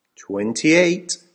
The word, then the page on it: twenty eight: American English